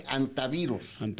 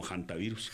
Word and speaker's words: hantavirus: Bueno, unos dicen antivirus porque la H es muda. Pero sí, en medicina, sí. Pues nosotros lo pronunciamos siempre es como hantavirus. – Hantavirus. – Porque acuérdense que incluso, la H es muda. Pero es hanta.